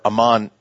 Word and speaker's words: Amman: As a matter of fact, their capital is pronounced a little bit different, but it's spelled the same, Ammon, or Amman, Jordan.